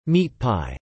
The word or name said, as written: meat pie